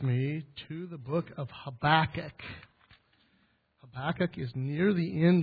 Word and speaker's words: Habakkuk: When you say Habakkuk, you have to say it like this, Habakkuk. Like a Jewish person would say it, Habakkuk.